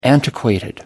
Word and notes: antiquated: American English

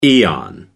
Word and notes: aeon: American English

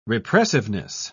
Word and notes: repressiveness: British English

